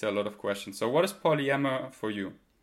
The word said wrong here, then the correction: polyamory: Polyamory. – Polyamory, is that how you say it? Polyamory.